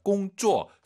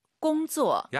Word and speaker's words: 工作: 일하다 꽁쪼 쌍지읒 하지 마시고 工作。 – 약간 지은 느낌으로 힘 빼고 발음하시는 게 포인트입니다.